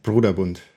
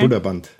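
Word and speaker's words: Brøderbund: So is it according to Wikipedia? It's pronounced Broderbund. – Oh, okay. – Broderbund.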